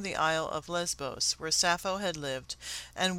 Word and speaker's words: Sappho: My journey began in Athens, where I entered a bookstore in the Plaka and inquired about Sappho, pronouncing her name in the Greek way, Sappho.